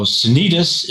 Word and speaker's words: Sanitas: So Sanitas is the standard normal pronunciation, which is the Spanish pronunciation, accent on the second to the last syllable, which is common in Colorado.